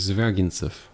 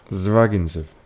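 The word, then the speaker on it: Zvyagintsev: Og det er en film af Andrei Svagit... Hvordan udtaler du det? – Zvagintsev. – Zvagintsev. Ja, det gør man også. Zvagintsev.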